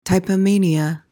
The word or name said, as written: typomania